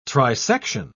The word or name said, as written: trisection